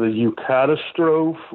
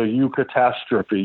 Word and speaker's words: eucatastrophe: And Tolkien's point was that the storyteller incorporates a moment in the tale that is known as the eucatastrophe or the eucatastrophe. You'll hear it pronounced different ways.